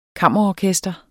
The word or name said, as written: kammerorkester